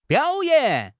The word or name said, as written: biao3 yan3